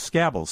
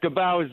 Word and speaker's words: Scabal: Now, you work with a company called Scabble, C-S-A-B-A-L. Did I pronounce that correctly? – There's a letter or two wrong. It's pronounced Scabal, S-C-A-B-A-L, Scabal.